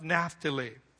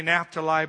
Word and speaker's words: Naphtali: In Deuteronomy 33:23, we have a word given to the tribe of Naphtali. Some say Naphtali, but it's actually pronounced Naphtali.